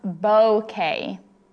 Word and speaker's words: bouquet: Vera is pulling a buddy and getting some information from Google, uh, going to our last question, saying that Google says it is pronounced bouquet. Okay. Just so we all know, we have been incorrect.